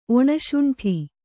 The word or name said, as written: ಒಣಶೊಂಠಿ